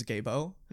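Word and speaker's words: Gebo: We have the rune Gifu, the actual Old Norse pronunciation of it is Gabo, mm-hmm.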